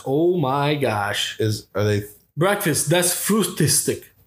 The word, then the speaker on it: Frühstück: Das Frühstück.